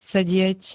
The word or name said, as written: sedieť